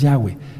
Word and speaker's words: Yahweh: Su nombre es Yahweh, yod hei Baf Hei. Ya ministré hace un momentito, antes de sentarme, que la letra Yod jamás sonará como J, jamás. No, su nombre es Yahweh.